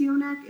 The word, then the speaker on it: Yoneq: I think it's Yonek. It's spelled Y-O-N-E-Q. – That sounds right. – Right? – Yeah. – That sounds like Yonek.